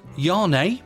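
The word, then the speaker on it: Zhané: From the album pronounced Yarné, this will be Yarné with a Z.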